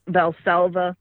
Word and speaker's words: Valsalva: Val- Valsalva maneuver.